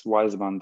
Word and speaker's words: Wizman: Last name, it's Wizman, Wizman.